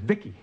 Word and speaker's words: Vicki: V-I-C-I, I, I pronounce it Vicky.